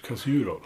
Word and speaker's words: Kasurol: Jag tänkte namnet först, Kasurol. Mm. – Var, var, var kommer det ifrån? uttalar jag det rätt först? – Ja, det är det. det, är rätt.